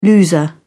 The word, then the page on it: loser: British English